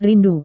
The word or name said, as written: rindu